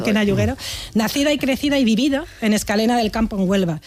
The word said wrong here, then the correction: Escacena: Escacena. Mira que te lo he dicho mal varias veces, por ahí está, Escacena.